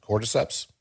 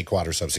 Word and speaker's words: Cordyceps: Cordyceps. There it is. Yeah. I did not want to say quadriceps again.